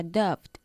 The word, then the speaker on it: doubt: Last week, they had a doubt. – Vale, me alegro que lo hayas pronunciado así, porque la B en la palabra doubt, duda, es muda, ¿vale?